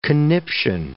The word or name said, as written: conniption